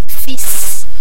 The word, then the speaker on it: fils: Fils. – So F-I-L-S. – F-I-L-S, yeah.